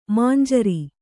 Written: ಮಂಜರಿ

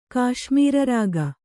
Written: ಕಾಶ್ಮೀರರಾಗ

